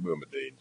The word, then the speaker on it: Boumediene: Yeah, so it was at issue in Boumediene, and I'm going to defend that pronunciation, by the way. When the case first came before us, Judge Randolph and I agreed we would pronounce it. However, the first lawyer pronounced it that got up, and he said Boumediene. So I've stuck with that ever since.